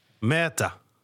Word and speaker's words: Merta: En de nieuwe linksback, hoe spreek je de achternaam eigenlijk echt officieel uit? – Ja, zeg eens. – Merta.